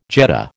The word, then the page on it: jet: American English